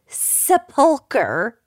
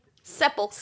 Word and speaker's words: sepulcher: To keep air and to keep out the air and the water, the tomb builders encased her entire cypress wood sepulcher. Am I saying that right? Mm-hmm. Sepulcher. Sepulcher.